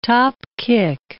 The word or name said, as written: topkick